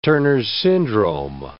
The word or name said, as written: Turner's syndrome